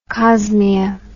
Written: cosmea

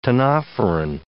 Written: ctenophoran

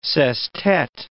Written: sestet

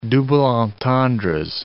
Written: double entendres

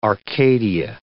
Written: arcadia

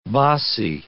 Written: bassi

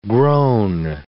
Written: grown

